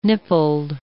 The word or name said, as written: nippled